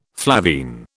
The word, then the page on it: flavine: British English